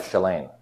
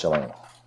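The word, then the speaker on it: Källén: So I'm not Scandinavian, I don't know how to pronounce things, but when I was a student, Weinberg pronounced that Shalane, and he apparently knew Shalane. So my understanding is that's pronounced Shalane.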